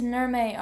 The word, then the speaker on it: Nirmay: Alright, so next one is Nirme Art. – Wait, what? How do you spell that? – N-I-R-M-A-Y.